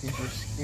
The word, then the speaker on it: Idris: With Iris Elba, I get the name right. Idris. Idris. Idris. I still can't get it. I said Iris again.